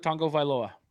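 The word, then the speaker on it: Tagovailoa: Tua Tango Vailoa.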